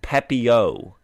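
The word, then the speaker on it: Pepiot: and I did find a pronunciation. You were very close. It is Pepio.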